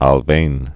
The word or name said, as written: Alfven